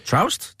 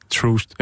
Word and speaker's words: Troost: Og øh, der er han på øh, sin lokale bar, eller tidligere lokale bar, Troost, tror jeg det udtales. Og, øh, Troust? – T-R-O-U-S-T? – Nej. Dobbelt O. okay, så. Ikke tr- eller Trout. I don't know. Ørdebar. Nej, Troost.